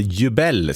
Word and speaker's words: Jubel: Jubel var det där. Och, eller Jubel ska det uttalas, just det.